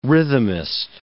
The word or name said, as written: rhythmist